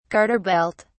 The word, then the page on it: garter belt: British English